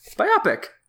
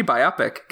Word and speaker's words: biopic: Biopic. – It's like a pick. – Yeah. Biology, biopic. Got it.